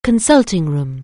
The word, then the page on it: consulting room: anglais